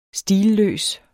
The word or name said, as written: stilløs